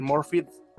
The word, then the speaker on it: Morfydd: pero me parece que está, a mí sí me parece que Morfydd, porque se pronuncia con diciendo la última D, tienes que decir Morfydd Clark.